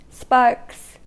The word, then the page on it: sparks: American English